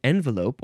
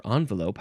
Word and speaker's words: envelope: Envelope, or doesn't say how to pronounce it here, envelope or envelope, however, has been in English for nearly 300 years, plenty of time for it to become completely anglicized and for both of its pronunciations to win respectability.